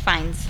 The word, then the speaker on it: Fiennes: Am I saying that right? – His last name is Fiennes.